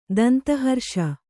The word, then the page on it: ದಂತಹರ್ಷ: Kannada